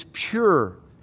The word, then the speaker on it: pur: The Assyrian word for lot is pur, P-U-R, not P-U-R-E, pur or pronounced pur.